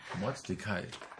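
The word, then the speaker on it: Mortdecai: Ach ja, Mord DK. Wie spricht man das aus? – Mordecai? – Mordecai.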